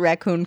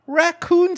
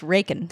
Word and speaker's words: raccoon: David Crockett's classic raccoon skin cap. That's how you pronounce it. Raccoon? You say raccoon skin cap? Raccoon. It's pronounced Rakin. That's also acceptable. Those are both great, and let's stop trying to come up with what else it could yeah.